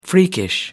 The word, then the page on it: freakish: American English